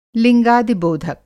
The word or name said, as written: लिंगादिबोधक